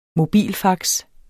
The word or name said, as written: mobilfax